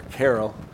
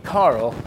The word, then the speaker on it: Caryl: And she kept her maiden name because in Colorado, her name is spelled C-A-R-Y-L. Most people will see it and call her Carol, but a lot of people, if they're from Colorado, say Carl, because there's a Ken Carl Ranch.